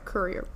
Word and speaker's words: Courier: This time I said it right. Courier.